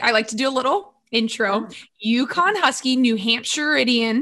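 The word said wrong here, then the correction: New Hampshirite: Is that how you say it? – New Hampshireite.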